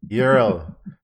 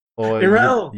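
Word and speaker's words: Yrel: Yurel. – Yurel. – Yurel. Yurel. I don't know. There are some people that say it very differently, but we're going to go with the Blizzard pronunciation is Yurel.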